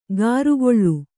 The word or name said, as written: ಗಾರುಗೊಳ್ಳು